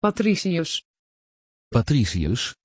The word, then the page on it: patricius: Dutch